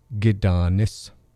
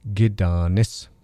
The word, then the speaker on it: Gidanis: It becomes Gidanis, Gidanis, and the G is you, and the Danis part is a daughter, your daughter, Gidanis.